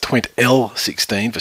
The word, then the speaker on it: TwentL16: Well, it's pronounced TwentL16 for starters, and a hashtag makes no sense.